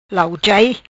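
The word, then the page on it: Lauchai: patua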